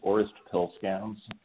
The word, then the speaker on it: Orest Pilskalns: Yeah, it's Orest Pilskalns, and it's a Latvian name, but I grew up in Montana, and that's where we're based right now.